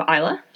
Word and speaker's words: Islay: Up next, for our next region, we have Islay. Islay is spelled I-S-L-A-Y, and Islay is an island on the western side of the Hebrides.